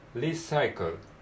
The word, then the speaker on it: recycle: recycle、 recycle。でリサイクルのクールの部分は、えー、発音記号的には KL ですね、えー、子音が 2 つ連続で続きます recycle recycle recycle the ball recycle the balls はいじゃあここまでを、えー、通して読んでみます Taking our own bags to stores is the easiest way.